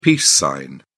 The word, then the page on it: peace sign: British English